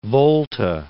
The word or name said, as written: Volta